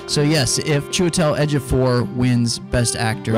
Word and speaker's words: Chiwetel: Well, if Cheetawal Ejiofor wins. – What? A Cheetawal to die for? To die for a proper pronunciation. It's Chiwetel Ejiofor.